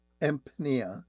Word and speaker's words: Empnia: Eric actually works for a company called Empnia, that is spelled E-M-P, like in Peter, N-I-A. Am I pronouncing that correctly, Eric? – That's correct, yes.